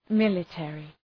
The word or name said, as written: military